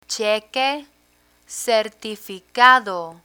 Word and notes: cheque certificado: spanish

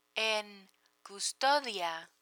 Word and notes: en custodia: Spanish

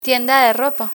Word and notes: tienda de ropa: spanish

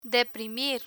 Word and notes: deprimir: spanish

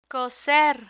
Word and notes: coser: spanish

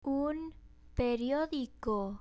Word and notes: un periodico: Spanish

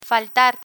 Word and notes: faltar: Spanish

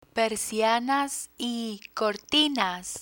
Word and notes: persianas y cortinas: Spanish

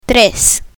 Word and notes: tres: Spanish

